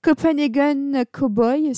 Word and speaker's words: Copenhagen Cowboy: Copenhagen Cowboy, si je, si je le dis bien. C'est bien ça, Quentin Oui, c'est ça.